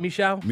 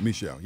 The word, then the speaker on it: Michaud: Did I say it right, Michaud? – Michaud, yeah.